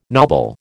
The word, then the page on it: knobble: American English